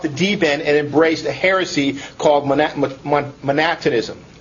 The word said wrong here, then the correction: Montanism: Montanism is how it's pronounced. Montanism.